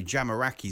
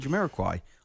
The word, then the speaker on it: Jamiroquai: But I was on the other end of that because apparently Jamiroquai is not the right way to say Jamiroquai.